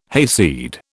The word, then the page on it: hayseed: British English